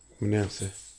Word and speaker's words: Manasseh: Manasseh. – Manasseh.